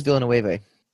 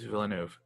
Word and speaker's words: Villeneuve: I thought it was Villanueva. – It's not. It's Villeneuve.